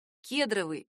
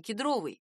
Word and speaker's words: кедровый: Например, вариант «кедровый» от слова «кедр» уже считается устаревшим. Литературная норма — «кедровый».